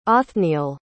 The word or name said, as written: othniel